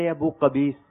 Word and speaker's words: ابو قبیس: قبیس. تھوڑی تلفظ کی مسٹیک ہو رہی ہے. صحیح تلفظ سے بتا دیں اگر تو تحفہ مل جائے گا. – جبل ابو قبیس. – جبل ابو قبیس جواب درست ہے.